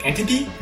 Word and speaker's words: Entebbe: I believe it's pronounced like Entebbe, but the country of Uganda, which is a small country in Central Africa.